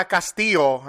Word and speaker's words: Callisto: My guest today, she's actor phenomenon Vada Callisto. Am I pronouncing it correctly? – Callisto. – I'm sorry. You know what? I'm thinking of Castillo, and it's Callisto.